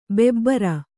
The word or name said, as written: ಬಬ್ಬರಿ